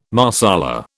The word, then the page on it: Marsala: British English